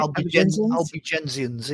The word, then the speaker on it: Albigensians: But yeah, so I mean, you know, getting into the Albigensians. Did I say that right? – Albigensians.